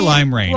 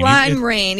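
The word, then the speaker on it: limera1n: Lime Rain. – Lime Rain, even though it's with a one.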